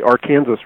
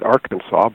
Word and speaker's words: Arkansas: which they call the Arkansas River. Other people would pronounce it Arkansas, but they have their pride in their way of saying it.